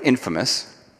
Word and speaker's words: infamous: And in fact, uh, by those to whom he preached, he was considered more infamous, or pronounced correctly, infamous, which means being well known for something bad, right?